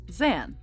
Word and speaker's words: Xan: Okay, how would you pronounce X-A-N? – Zan. – Zan? Okay, that's how I've been pronouncing it.